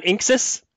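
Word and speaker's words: INXS: You pronounced it Inx, not Inxus? – I don't know.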